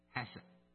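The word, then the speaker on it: hesed (חסד): 慈爱这个字，它在旧约里面它的那个发音是 hesed。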